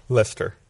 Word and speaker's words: Leicester: Leicester? – Leicester.